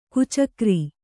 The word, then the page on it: ಗೀಚುಗೆರೆ: Kannada